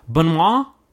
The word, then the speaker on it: Benoit: Benoit? It's like a B, Benoit. – Like a soft U instead of an E.